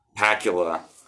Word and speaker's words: Pakula: Pakula? – Pakula. – Like Scott Bakula, but now Pakula.